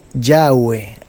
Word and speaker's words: Yahweh: Yahweh, así es como lo pronuncian los mesiánicos, los que son judíos.